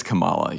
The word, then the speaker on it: Kamala: It is Kamala.